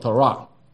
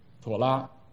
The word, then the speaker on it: Torah: 但针对以色列人来说，整个摩西五经是一本书，它有个名称叫做 Torah，妥拉，或者有人念成 Torah。